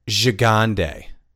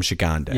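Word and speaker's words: Gigandet: Cam Gigande. That's how you pronounce his last name. Cam Gigande.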